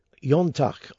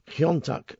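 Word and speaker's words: Iontach: Now, Yontach, or Kiontach, as I was taught to pronounce it, is the Irish for wonderful or strange.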